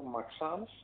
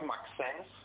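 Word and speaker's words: Maxence: Μαξάν Λακρουά, όχι Μαξέν, που τα ακούω και μου γυρνάνε τα άντρα. Είναι Μαξάν Λακρουά.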